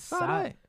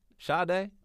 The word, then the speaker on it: Sade: Who the hell is Sade? Sade, bro. What is wrong with you? – Wait, that's how you pronounce Sade? It is Sade.